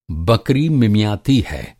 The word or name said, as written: बकरी